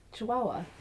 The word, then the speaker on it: Chihuahua: Chihuahua.